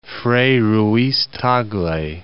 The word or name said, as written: Frei Ruiz-Tagle